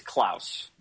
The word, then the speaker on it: Claus: Is it pronounced Claus like Santa? – It can be, but usually it's Klaus.